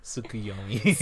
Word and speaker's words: Tsukuyomi: I can never say that right. Tsukuyomi? – Tsukuyomi. – Tsukuyomi.